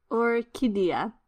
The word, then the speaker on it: Orchidea: Orchidea. You kind of need to be able to roll your R's to pronounce it, which I am not actually capable of doing. So, Orchidea Divina, but there should be an R roll in there.